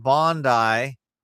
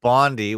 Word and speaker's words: Bondi: We're still on the Brown and Bondi shooting event. I think I got an email from someone who said North Americans pronounce it Bondi, which is wrong. It's Bondi.